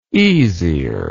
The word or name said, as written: easier